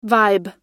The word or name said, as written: vibe